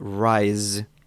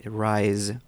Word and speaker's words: rise: So if you remember the alphabet, um, at the end, like the, the letter Z, that is the pronunciation of the final word at the end of rise is Z, Z, like W, X, Y, and Z from the ABC, Z. Then you have rise when you pronounce it right, rise, okay?